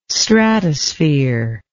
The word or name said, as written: stratosphere